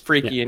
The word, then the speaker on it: Freki: Maybe Freki. – Who are these guys? – Maybe Freki, honestly. So Freki is spelled F-R-E-K-I, could easily be Freki, and Gary is spelled G-E-R-I.